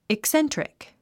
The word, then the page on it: eccentric: ikséntrik; イクセントリック